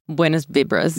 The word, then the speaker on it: buenas vibras: It's buenos vibes. – Only buenas vibras. Yes, thank you. Buenas vibras. Okay. I just want to make sure everyone knows how to pronounce that.